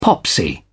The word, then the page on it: popsy: British English